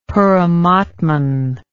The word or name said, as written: paramatman